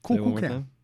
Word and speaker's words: Kukulkan: Kukulkan. – Are you saying it right? – Yeah, I'm saying it right. Kukulkan.